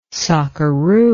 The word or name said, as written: sockeroo